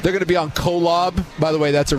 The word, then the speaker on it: Kolob: Oh, K-O-L-B, Kolob.